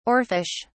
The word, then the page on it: oarfish: British English